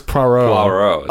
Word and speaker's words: Poirot: I didn't know that was how it's pronounced. – I don't think that's how it's pronounced. – I thought it was Poirot. – Oh, that's right. That's right. You know what I mean. Poirot.